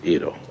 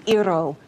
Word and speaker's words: gyro: Is it gyro or gyro? – It's Iro, okay. Ir and ro. – Ir, thank you. Got it. Mob guy smuggling drugs through an Iro shop.